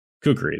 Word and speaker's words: Kukri: Kukri. – Thank you. – Yeah. – Kukri, Kukri, whatever it is.